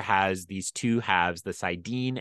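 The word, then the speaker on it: saidin: Sidar is correct. Just okay. Pronunciation, Sidin. – Sidin.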